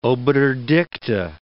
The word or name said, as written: obiter dicta